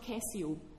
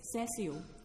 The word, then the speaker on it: Cessio: It's not Cessio with a hard C, a deformed pronunciation. It's Cessio. De Fideli, F-I-D-E-L-I.